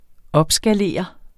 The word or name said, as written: opskalere